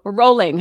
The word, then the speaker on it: Rowling: So just remember J.K. Rowling, or Rowling, how it's actually pronounced, when the going gets tough.